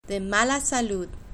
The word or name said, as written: de mala salud